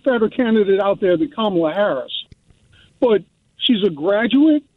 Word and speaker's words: Kamala: And it's Kamala, not Kamala. It's Kamala.